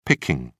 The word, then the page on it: picking: British English